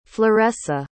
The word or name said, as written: floressa